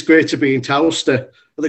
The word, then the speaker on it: Towcester: And for 2022, we're looking at a three-day festival in Whittlebury Park, again, in Towcester. Is that how you pronounce that word? – Towcester.